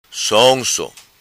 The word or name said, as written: Sonso